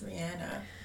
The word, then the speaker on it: Rihanna: Her name isn't Rihanna. It's Rihanna.